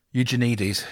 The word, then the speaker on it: Eugenides: Now, a really good example is The Virgin Suicides by Jeffrey Eugenides. I don't think I'm saying that correctly. – Show me the name. Show me the name. Eugenides. – That's the one.